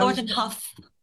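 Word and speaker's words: Thornton Hough: Thornton House? – Thornton Huff. – Thornton Huff, yes, I thought it might be plural.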